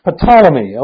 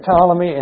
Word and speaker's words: Ptolemy: The T is silent, I think. It's Ptolemy, I think. – Ptolemy?